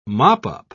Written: mop-up

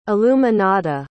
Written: iluminada

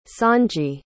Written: songy

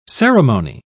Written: ceremony

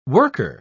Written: worker

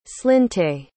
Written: slinte

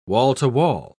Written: wall-to-wall

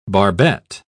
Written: barbette

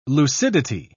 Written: lucidity

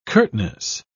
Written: curtness

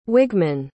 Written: wigman

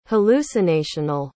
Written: hallucinational

